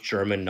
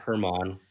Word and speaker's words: German: And yes, it is pronounced German, not Herman.